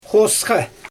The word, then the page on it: xosxa: Xyzyl